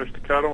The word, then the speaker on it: Llano Estacado: they founded Llano Estacado Winery. Did I say that right? – Yes, you did. Estacado Winery.